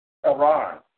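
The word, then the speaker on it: Aron (ארון): And the funny thing is, when you pronounce it in a Hebrew tongue, it sounds like you're saying Aaron. Aron. A-R-O-N. Or you can pronounce with that W-N sound coming out. Aron. So like Aaron's name.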